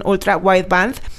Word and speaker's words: Ultra Wideband: Por lo menos también en Estados Unidos va a añadir Digital Key de 2.0 con Ultra Wideband.